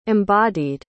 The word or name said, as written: embodied